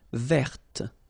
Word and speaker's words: verte: Feminine form, verte. So listen carefully. I don't say te, but it's t. Verte. Verte. Okay, so as usual, this final E only gives you the possibility to pronounce this t.